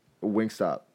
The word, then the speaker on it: Wingstop: Yeah, Wingstar. Was it Wingstar? – Yeah. Wingstop. – Wingstop. My bad.